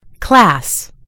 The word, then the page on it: class: American English